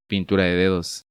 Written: Pintura de dedos

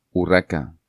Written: Urraca

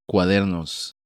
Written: Cuadernos

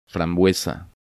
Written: Frambuesa

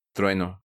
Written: Trueno

